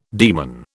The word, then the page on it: daemon: British English